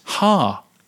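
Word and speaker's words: ha (ห้า): Also, five in Thai is pronounced ha.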